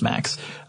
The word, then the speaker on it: MMACS: Uh, next, we have the Maintenance Mechanical Arm and Crew Systems Officer, or MAX, M-M-A-C-S, but it's pronounced MAX.